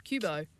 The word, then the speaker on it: Qoobo: It's called, I don't even know how to say it, Q O O B O. How would Qoobo. you say that? Qoobo. Qoobo.